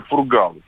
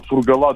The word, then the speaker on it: Фургал: Просто многим режет уши, когда неправильно произносит фамилию Фургал. Сергей Фургал именно на первую букву ударения, фур, на вторую Фургал, а не Фургал, не Фургала и так далее.